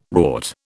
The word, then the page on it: wrought: British English